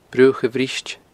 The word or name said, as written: Bruach a' Bhriost